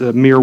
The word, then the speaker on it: Muir: They're called the Moore Woods. It's named after him. That's where the giant redwoods, Muir Woods, that's how they're pronounced.